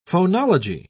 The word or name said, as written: phonology